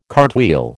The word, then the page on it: cartwheel: American English